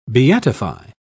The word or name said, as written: beatify